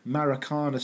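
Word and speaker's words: Maracanã: Recorded in front of 180 fucking thousand people on the 21st of April 1990 at the Maracana Stadium in Rio, Brazil, which I nearly pronounced as the Macarena Stadium, this is P.S.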